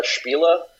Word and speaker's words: Spiele: What was it like to come over to Ulisses Spela? Um, is it Spiel or Spiela? I've heard it pronounced both ways. – Yeah, well, it, it, in German it's uh, Spiele, so yeah, I just call Ulisses North America probably be the best way.